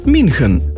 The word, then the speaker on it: München: Washington bomo izgovorili Washington, München pa München. Izjema so tuja zemljepisna imena, ki so v slovenskem kulturnem prostoru vse splošno znana in imamo zanje poslovenjena imena.